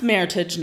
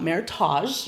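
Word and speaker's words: Meritage: And it is pronounced Maritage, not Maritage. You want people to know that because it was the word merit and heritage put together.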